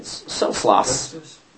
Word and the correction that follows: Wenceslas: Wenceslas, ignored the order and protected John Huss.